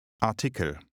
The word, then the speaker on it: Artikel: The German word for article is pronounced Artikel. – Say article. – Artikel. Der Artikel. – Keeping in mind that it's masculine, try to say, I just read an article. – Ich habe gerade einen Artikel gelesen.